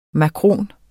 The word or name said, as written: makron